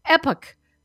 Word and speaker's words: Epoch: You know, of course, that we have as one of our sponsors, the Epoch Times. Some people, it's amazing. It has many pronunciations, epoch, epoch, epic, epic, all of those things.